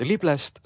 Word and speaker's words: Lible: Õpetaja rõhutas hääldades viimast silpi, nii et Liblest sai Lible.